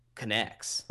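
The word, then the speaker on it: K'NEX: Connects. – Oh, they were just called Connects. – Yeah, do a Google search. It's spelled KNEX.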